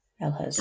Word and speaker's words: Ehlers: But it from when I've been told by the consultant, it was Elhurst. – Elhurst.